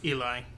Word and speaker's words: Ely: I think it's pronounced Eli. – Okay, because it was E-L-Y, and I was reading it as Eli, but I could understand somebody saying Ellie at the same time.